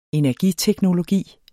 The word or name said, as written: energiteknologi